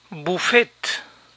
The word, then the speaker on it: buffet: Buffet, buffet, buffet. La ET final de estas palabras de origen francés se pronuncian A. Buffet, valet, beret.